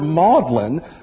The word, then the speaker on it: Magdalene: She's from the town of Magdala, but the word maudlin, which that English pronunciation of the word Magdalene, is a word that means, you know, sentimental or weepy.